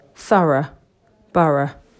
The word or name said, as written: Thorough borough